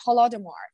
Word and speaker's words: Holodomor: Is that the Holodomor? Am I saying that right? – Absolutely right. Yeah, Holodomor.